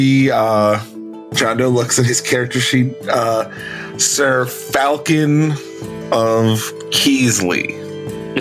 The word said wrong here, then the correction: Falcone: It's pronounced Falcone. – Oh, Sir Falcone.